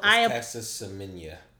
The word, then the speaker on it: Caster Semenya: Okay, so Caster Semenya, for those who don't know, and I don't know if I'm butchering the name, so it's Caster Semenya. – Caster Semenya? – Yeah.